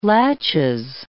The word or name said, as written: laches